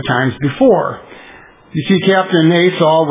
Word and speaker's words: Asoh: The date was November 22nd, 1968, and Captain Asaw, it's spelled A-S-O-H, he was a pilot for Japan Airlines.